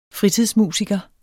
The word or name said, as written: fritidsmusiker